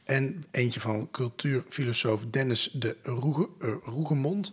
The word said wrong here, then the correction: Rougemont: Het zal vast niet zo uitgesproken. – Rougemont? Ja, het is een Zwitser, dus het zal wel op zijn Frans.